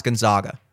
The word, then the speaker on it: Gonzaga: I think this is one of the first years that Gonzaga is not, is it Gonzaga? I think it's Gonzaga. I actually did look this up. It is pronounced Gonzaga. It sounds like Gazoon-tite.